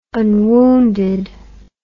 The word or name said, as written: unwounded